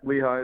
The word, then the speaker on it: Lehigh: And then they play Lehigh. Am I saying that right? – Yep, Lehigh is correct.